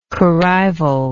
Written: corrivalry

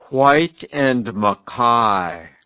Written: Whyte and Mackay

